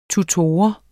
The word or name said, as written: tutor